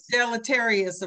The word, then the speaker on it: deleterious: how do you say the word, delirious? Deleterious effects. I can never say it. Deleterious effects. Is that it? Deleterious? – I believe so.